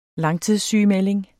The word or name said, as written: langtidssygemelding